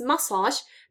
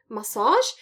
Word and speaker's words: massage: Word stress, accent, word stress is on the first syllable. It's massage, not massage. Even though this word probably does come from French and it should be stressed on the second syllable, it isn't.